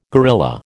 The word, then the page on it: guerrilla: American English